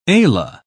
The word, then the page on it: ala: British English